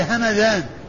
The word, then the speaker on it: همذان: أما ما كان نسبة إلى بلدة فهي همذان، مدينة من المدن اسمها همذان بالميم المفتوحة والذال المعجمة.